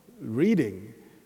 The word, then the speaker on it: Reading: It is spelled R-E-A-D-I-N-G. Reading, but they pronounce it Reading. It's the British thing, you know.